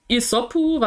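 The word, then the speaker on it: Isopu (イソップ): und Isopu, was so der, der japanische, die japanische Aussprache von Aesop ist, der griechische Dichter und Fabelschreiber.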